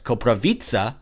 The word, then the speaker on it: Koprivica: It's Koprivica, like a pizza, as he said.